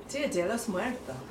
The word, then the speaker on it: Día de los Muertos: Día de los Muertos.